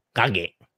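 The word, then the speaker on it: Kagge: กันในหลายๆมิตินะครับหนังสือที่ผมหยิบเอามาประกอบการพูดคุยในวันนี้นะครับก็คือหนังสือชื่อ Walking One Step at a Time นะครับเขียนโดยคุณเออร์ลิงคาเกะนะฮะคือถ้าเกิดว่าลองเอาคำเนี้ยชื่อของเขาเนี่ยนะครับซึ่งเป็นภาษาน่าจะเป็นชาวนอร์เวย์นะครับเป็นชาวนอร์เวย์เนี่ยแล้วเข้าไปพิมพ์หาการออกเสียงใน Google นะครับแล้วก็ให้ออกเสียงแบบนอร์เวย์เนี่ยเขาจะออกเสียงว่าคาเกะคาเกะอย่างเงี้ยนะซึ่งมันคล้ายภาษาญี่ปุ่นมาก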